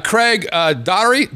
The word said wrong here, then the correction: Doherty: How do you pronounce that? What the hell are you trying to say? That'd be Doherty. – Doherty.